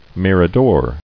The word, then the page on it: Mirador: mir·a·dor; Mir`a*dor"